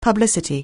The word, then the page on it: publicity: anglais